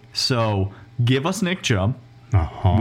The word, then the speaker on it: Chubb: Just stop the, right The Nick Chubb is the correct way to pronounce his name.